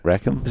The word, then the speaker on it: Rackham: Like the pirate. Rackham? – Is there a pirate called Rackham?